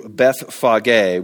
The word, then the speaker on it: Bethphage: As they approached Jerusalem and came to Bethphage, which I've been pronouncing Bethpage for all my whole life, on the Mount of Olives, this is just east of Jerusalem.